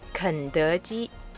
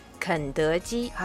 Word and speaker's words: 肯德基: 肯德基、肯德基。 – これも完全に音訳だと思うんですけど、漢字は否定文肯定文の肯定の肯、そして次が道徳の徳ですね。 – そうです。 – そして基礎の基と書いて発音が肯德基、肯德基。